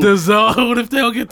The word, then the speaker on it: DAZN: D-A-Z-N. – Go ahead. – How do you pronounce what is it? – That? – D-A what? – It's on the Z-N. Paper. D-A-Z-N. That's the name of the streaming service. – I'm going with D-A-Z-N.